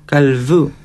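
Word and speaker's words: Kallfü: Una U, I, como la I francesa. Y tampoco una F tan marcada, es casi como una V, Kalfu. Incluso la L la pronuncian Kalfu.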